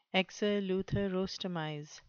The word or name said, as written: exeleutherostomize